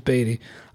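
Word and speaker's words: Baty: Now, James, speaking of the Mets, Brett Beatty, it's spelled Batty, but it is Beatty.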